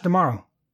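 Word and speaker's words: D'Amaro: Josh Dumaro. It rhymes with tomorrow.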